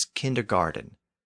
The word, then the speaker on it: kindergarten: In English, and especially in American English, it's pronounced with a D sound. So kindergarten becomes kindergarten, despite the fact that it's still spelled with a T.